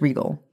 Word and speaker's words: Rigel: R-I-G-E-L. – It's spelled like Nigel, which is like, is it Rigel? But no, I'm pretty sure it's Regal.